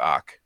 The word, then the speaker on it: Ock: Whereas, you know, Stan Lee said Doc rhymes with Ock.